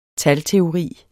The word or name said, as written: talteori